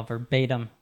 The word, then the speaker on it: verbatim: I like your pronunciation there as well, verbatim, with that American T there, right? – Thanks.